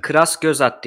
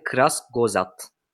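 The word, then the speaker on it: Kurzgesagt: Kras göz at diye okunuyormuş. Bir Vallahi izleyicimiz bilemiyorum. – söylemiş. Almanca bilen herhalde. Kras göz at.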